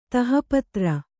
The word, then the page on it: ತಹಪತ್ರ: Kannada